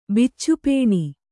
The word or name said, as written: ಬಿಚ್ಚುಪೇಣಿ